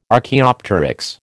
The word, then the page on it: Archaeopteryx: American English